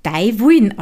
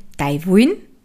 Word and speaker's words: De Wuin: und wird Dei Wuin ausgesprochen. Also eben Dei Wuin heißt deine Wolle.